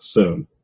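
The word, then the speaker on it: syn: But it comes out like S-O-O-N, by the way you say it, not sun. But to transliterate it comes out with a long U sound.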